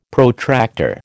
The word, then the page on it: Protractor: American English